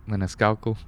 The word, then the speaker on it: Maniscalco: Maniscalco.